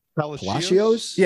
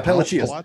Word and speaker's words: Palacios: The obvious one, but how do you pronounce the kid name, the outfielder with a P? – Richie Palacios? – Yeah, Palacios.